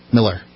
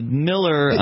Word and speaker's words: Mueller: M-U-E-L-L? – Yeah. – Okay, Miller. – Miller, okay. That Miller... – He may mispronounce his own name just to make people happy, and it certainly looks like Mueller, but it's Miller.